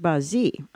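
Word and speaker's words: Bahjí: The shrine is located outside of Acre or Akko, Israel, and it is called Bashi, or the Shrine of Bashi, pronounced Bazi, which translates to delight in Arabic.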